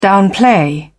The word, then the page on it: downplay: British English